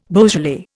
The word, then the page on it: Beaujolais: Indian